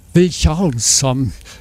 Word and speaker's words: Vilhjálmsson: Ja, das ist nicht so schwer. Thor Vilhjálmsson. – Vilhjálmsson. – Ja, das A mit dem Akzent wird wie Au ausgesprochen im modernen Isländischen.